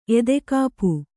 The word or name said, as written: ಎದೆಕಾಪು